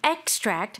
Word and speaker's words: extract: As a noun, extract. Stress on the first syllable.